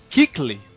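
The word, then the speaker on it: Kuechly: É engraçado que o nome dele se escreve K-U-S-C-H-L-Y, né? Mas eu vi um cara falando essa semana que se fala, na verdade, Kickley. A pronúncia do nome dele, a pronúncia meio esquisita do nome dele, não sei se o cara tava falando merda, não sei o que é, mas o cara tava falando Kickley.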